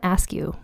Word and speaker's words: Askew: Now her last name is spelled A-S-K-E-W, like the word askew. But I asked on Twitter and 2 people both said they think it's pronounced Askew. And I think that's how I've heard other people with that as a surname pronounce their surname.